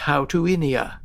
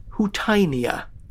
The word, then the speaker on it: Houttuynia: A little volume I have dating from the early 20th century tells me that it should indeed be pronounced Houtuinia. Ask a computer search engine for the correct pronunciation, and you'll get Houtinia, which, to be honest, is a darn sight easier to say.